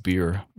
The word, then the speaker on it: Bier: I do know that Germans spell beer, B-I-E-R, but it's pronounced beer, so.